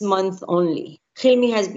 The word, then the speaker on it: Hilmi: In the novel, we have the character of Liat, and she meets Hilmi. Is that how you pronounce it? – Exactly, both Liat and Hilmi.